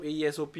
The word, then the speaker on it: Aesop: AESOP.